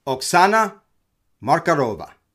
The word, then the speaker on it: Oksana Markarova: And from what I understand, it's phonetic. Oksana Markarova.